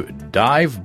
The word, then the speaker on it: Divebear: Remember to go to divebear.ch. That's divebar.ch. It's spelled like dive bar, but it's dive bear, to find out more about what they're up to.